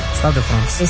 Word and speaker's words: Stade de France: Stade de France. – Stade de France.